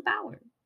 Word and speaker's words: Blauer: But it's the Balver. – It's spelled like Blower, this. – Blower. The Balver, yes, thank you. – Yes, spelled like that. B-L-A-U-E-R.